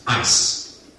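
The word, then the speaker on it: ice (εἰς): You see, the word for is from the Greek word that sounds like the English word ice. E-I-S, but it's pronounced ice.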